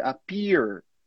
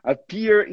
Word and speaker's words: appear: A pronúncia americana seria appear, appear. Se eu falasse isso, você ia pegar, né? Appear in the photo. Mas no inglês britânico, a gente não pronuncia aquele R, simplesmente fala appear, appear.